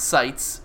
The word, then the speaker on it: Sites: Rob Sites, pronounced like Sites Bill, as in gun sights.